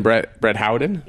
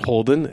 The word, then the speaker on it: Brett Howden: And Brett Howden? – Yep. – Not Holden as...